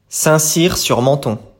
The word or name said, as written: Saint-Cyr-sur-Menthon